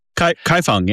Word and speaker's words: Kaifeng: We're also hoping to go to, and help me with the pronunciation, is it Kaifeng in Henan province? – Kaifeng, yeah, beautiful.